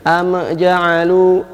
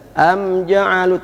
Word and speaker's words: am ja'alu: Ada beberapa pembacaan yang salah, yaitu mim dibaca dengan ada memantul, ada qalqalah, sehingga dia bacanya am ja'alu, am ja'alu. – Ini salah. Am harus jelas. – Am ja'alu tanpa ada pantulan. Am ja'alu.